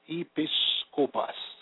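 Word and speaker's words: episcopos (ἐπίσκοπος): The Greek word is episcopos, pronounced episcopos, meaning superintendent, officer in charge, or a general in charge, or meaning ears of the church, or an overseer in the church.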